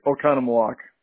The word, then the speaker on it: Oconomowoc: I'm going to butcher this name. Is it Oconomowoc? – Oconomowoc. – Oconomowoc. Okay, I'll get it down.